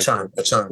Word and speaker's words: Achane: Uh, Aikney, is that how you say his name? De- De'Von Achane. And then they got Raheem Mostert. Achane, Okay.